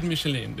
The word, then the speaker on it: Michelin: Och eh, vinnaren här, Eskomat och människor, har ju även en stjärna i, Kid, hur uttalas det? Guide Michelin? Guide Michelin, det är som däcket.